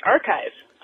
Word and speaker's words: arXiv: I was greatly amused to hear the description of the physics preprint collection called ARXIV. It's actually pronounced archive, which is kind of cool.